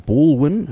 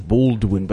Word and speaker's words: Ballwin: Ballwin. Not some people mispronounce it or misspell it as Baldwin, but this is there's no D in it.